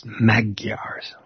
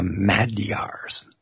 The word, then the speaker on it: Magyar: Now, you'll see the word Magyar, and it's spelled M-A-G-Y-A-R. And some people will automatically say Magyars, like there's a hard G in it. But it's a soft G, and it comes out sounding like a D.